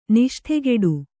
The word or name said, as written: ನಿಷ್ಠೆಗೆಡು